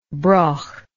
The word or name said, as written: broch